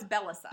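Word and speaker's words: Bellesa: It's Bellissima. – I think that's how you say it. And okay. I will put in the show notes how you spell it. But basically. – Oh, no, it's Bellissa.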